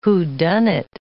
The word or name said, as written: whodunit